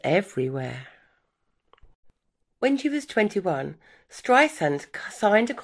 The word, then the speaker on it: Streisand: Okay, well, according to Streisand, her surname is pronounced with an S sound, like sand on the beach, not the Z sound often used.